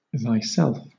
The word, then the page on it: thyself: British English